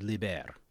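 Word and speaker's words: līber: Liber, excuse me, which means free, long I.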